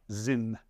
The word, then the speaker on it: Sinn: Zin. I think it's pronounced Z-I-N, but spelled S-I-N-N.